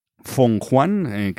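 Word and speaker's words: Fenghuang: Aún así, como decía, vamos a hablar en este caso de Shenmue 3 y de Chouwu, de este Feng Huan, que es como se pronuncia realmente,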